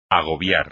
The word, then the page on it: agobiar: espagnol